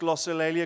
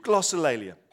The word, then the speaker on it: glossolalia: I know it's pronounced glossolalia because I looked it up this morning on YouTube, glossolalia.